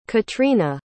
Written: caitríona

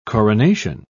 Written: coronation

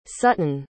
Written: sutton

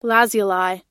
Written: lazuli

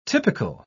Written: typical

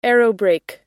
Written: aerobrake

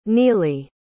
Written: nili

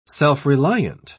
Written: self-reliant

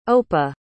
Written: opa

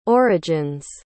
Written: origins

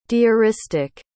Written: diaristic

